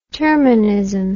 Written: terminism

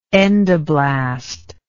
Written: endoblast

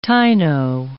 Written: Taino

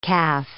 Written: calf

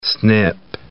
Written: SNP

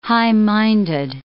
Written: high-minded

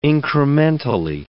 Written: incrementally

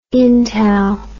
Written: intal

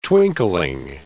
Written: twinkling

